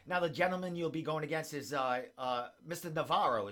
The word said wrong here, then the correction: Navarrete: Is that how you say his name, Christos? – Yeah, Mike Navarrete. – Navarrete, I'm sorry.